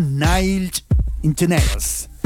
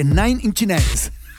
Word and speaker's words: Nine Inch Nails: Viernes libre y suena Nine Inch Nails. Lo dije mal, ¿eh? Porque Nine Inch Nails.